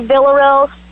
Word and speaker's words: Villarreal: And if anybody wants to follow me, my name is Cindy Villarreal, V-I-L-L-A-R-R-E-A-L.